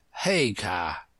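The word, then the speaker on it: Haigha: His name is Hagar. – He pronounced it so as to rhyme with Maya.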